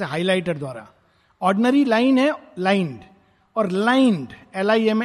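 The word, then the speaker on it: limned: word use किया लाइंड इसका pronunciation लाइंड है लेकिन इसकी स्पेलिंग है एल आई एम N E D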